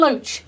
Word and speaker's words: Loach: Ken Loach. – Ken Loach. He's a great character, is Ken Loach, isn't he? – Which rhymes with Roach.